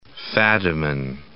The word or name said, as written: Fadiman